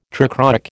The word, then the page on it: trichroic: American English